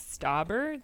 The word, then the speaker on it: Stauber: But I found this song called Buttercup by Jack Stauber, S-T-A-U-B-E-R.